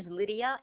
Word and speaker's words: Lydia: First name is Lydia, L-Y-D-I-A, and the last name, I'm going to have to spell it for you.